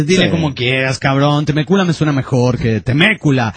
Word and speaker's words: Temécula: Temécula, yo sé, sí, más cariñoso, así que nos quedaremos con Temécula, Dice, bueno, con el acento en la segunda, Bueno, es todo.